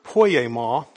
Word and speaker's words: poiema: The word here that's translated workmanship is the word poiema. That's how it's pronounced in the Greek.